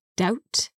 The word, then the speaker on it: doubt: And be careful, the word doubt is spelt D-O-U-B-T. So a silent B there.